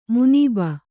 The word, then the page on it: ಮುನೀಬ: munība